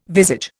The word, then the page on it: visage: Indian